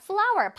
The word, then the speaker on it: flour: F-L-O-U-R. 바로 flower입니다. 자, flower. 발음은 꽃 flower랑 같은데 스펠링이 다르죠? 그치만 발음은 같아요.